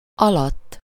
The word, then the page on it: dessous: France; [də.su]